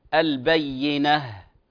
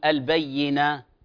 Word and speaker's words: البينة: يعني الآن ستكثر معنا في هذه السورة البينة مطهرة، فهذه الهاء الأصل فيها أن تنطق هاء، فلا نصد اللسان ويرجع إلى مخرج الألف، فنقول ماذا؟ البينة بالهاء، ولا نقول البينة أو مطهرة وهكذا.